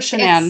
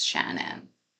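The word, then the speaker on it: Shannan: I've heard Shanann. – It's spelled like Shanann, but I believe it is Shannon Gilbert.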